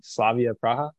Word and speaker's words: Slavia Praha: So, transitioning back into professional over in the Czech Republic, SK Slavia Praha.